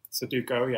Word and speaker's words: Sudoku: Sudoku? – Sudoku, yeah.